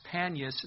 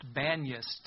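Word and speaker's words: Banias: That's why it's actually known as Panius. It's actually pronounced Banius today.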